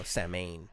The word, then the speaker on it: Samhain: And not a Mr. Hain, like his first name is Sam. – No. – No, Samhain.